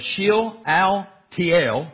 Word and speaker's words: Shealtiel: Then Zerubbabel, the son of Shealtiel,